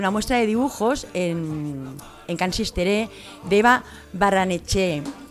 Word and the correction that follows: Barreneche: que ahí lo digo bien, Barreneche, sí. Sí.